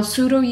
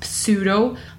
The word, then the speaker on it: sudo: And yes, I know it's not pronounced pseudo, but if I pronounced it correctly as simply sudo root user, it would have been kind of confusing. So sorry about the mispronunciation, but I had to get that silent P across.